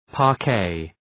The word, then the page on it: parquet: {pɑ:r’keı}